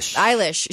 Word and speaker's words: Eilish: And then Billie Eilish, is how you say her name? – Eilish.